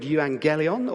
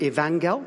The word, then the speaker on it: euangelion: So gospel literally comes from the Greek word euangelion, or sometimes it's pronounced evangel.